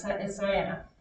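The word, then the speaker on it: Ciena: I always say the name right. It's Syana.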